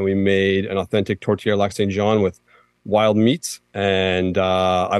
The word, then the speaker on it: Lac Saint-Jean: And so I was told that if I wanted to see a very authentic tortillere in Quebec, I should go to Lac Saint-Jean, if I'm saying that correctly. – You are saying that correctly, yep. – They have a tortillere Lac Saint-Jean.